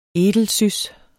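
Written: edelsüsspaprika

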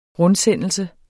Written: rundsendelse

